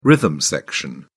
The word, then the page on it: rhythm section: British English